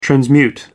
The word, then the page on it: transmute: American English